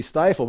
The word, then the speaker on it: Stiefel: Well, that's German. It's the I-E. So you want to say Stiefel, but you also want to say Weimers. But you see, it's the same. W-I-E is pronounced Weimers in German. And Stiefel, that's Stiefel, S-T-I-E.